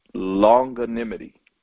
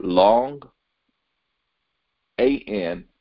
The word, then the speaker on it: longanimity: And so as we look closer in this word forbearance, it has one definition that I specifically want to bring out, and it is pronounced longanimity, longanimity. So that is L as in leaf, the word long, A-N-I-M-I-T-Y, okay, longanimity.